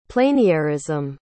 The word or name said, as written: pleinairism